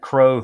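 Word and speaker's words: Crowe: I call him Crow because of Admiral Crow. That's how you pronounce his name, former CNO and former Chairman of Joint Chiefs of Staff.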